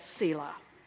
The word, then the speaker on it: Selah: Now, we're not real sure how it's pronounced. Some say Selah, Selah, Selah, Selah. The Hebrew people back in the day knew how it was pronounced, but most scholars today agree that it's Selah.